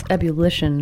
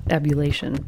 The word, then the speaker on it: ebullition: I was saying it wrong, actually. It's ebullition, not ebullation.